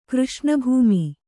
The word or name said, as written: ಕೃಷ್ಣಭೂಮಿ